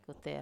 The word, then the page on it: kuter: wamesa